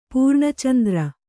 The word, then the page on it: ಪೂರ್ಣಚಂದ್ರ: pūrṇa candra